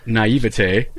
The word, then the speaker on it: naivete: We had tons and tons and tons of people message us with the correct pronunciation of naivete.